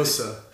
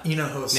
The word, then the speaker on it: Hinojosa: Hinojosa. – Huh? – Hinojosa. – Now that's spelled with an H, but the H is silent. So Hinojosa.